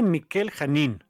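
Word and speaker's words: Mikel Janín: Superman Worlds of War, de Philip Kennedy Johnson y Michael Janin. Midnighter, de Becky Cloonan. – Perdón, se pronuncia Miquel Janin, español.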